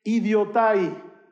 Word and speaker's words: idiotai: Eso en griego, porque Hechos ha sido escrito en griego, tú lo lees y utiliza una palabra que se pronuncia idiotai.